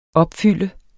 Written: opfylde

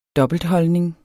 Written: dobbeltholdning

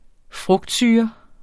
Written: frugtsyre

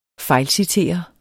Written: fejlcitere

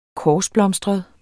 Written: korsblomstret

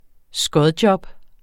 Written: skodjob

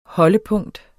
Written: HOLDEPUNKTS

